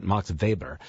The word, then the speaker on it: Weber: It was written by a gentleman named, we'd look at his name, Susie, and pronounce it Max Weber. He was from Germany, I believe, or Austria, maybe, but he pronounced it Max Weber.